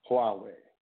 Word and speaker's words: Huawei: moves to relax restrictions on sales to Chinese telecommunications giant Huawei. That's how y'all pronounce that. I finally got it.